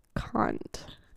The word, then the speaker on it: Kant: Enunciate the A in Kant. A little bit more. – Kant. Let's do ASMR. – Okay, ready? – Welcome to our show. This Immanuel is Kant.